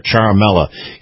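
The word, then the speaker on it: Ciaramella: Now I want to go with the identified whistleblower that supposedly started all this by the name of Eric Charamella. Now, his name isn't spelt the way it sounds, but that's the way you pronounce it, Eric Charamella.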